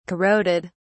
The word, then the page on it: corroded: British English